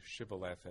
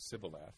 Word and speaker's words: shibboleth: It's a relevant word because it actually is phonetically relevant, the difference between shibboleth and sibboleth.